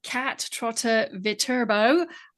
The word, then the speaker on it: Kat Trotter-Viterbo: Kat Trotter-Viterbo from 8 Salon.